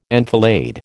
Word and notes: enfilade: American English

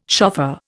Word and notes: shofar: Indian

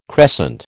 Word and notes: crescent: American English

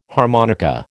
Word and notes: harmonica: American English

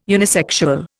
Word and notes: unisexual: Indian

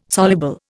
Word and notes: soluble: Indian

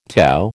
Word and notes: tau: American English

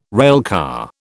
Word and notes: railcar: British English

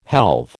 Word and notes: helve: American English

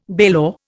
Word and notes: bailor: Indian

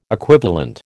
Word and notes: equivalent: American English

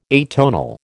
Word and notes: atonal: American English

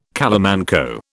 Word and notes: calamanco: British English

